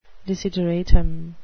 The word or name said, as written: desideratum